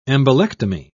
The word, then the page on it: embolectomy: British English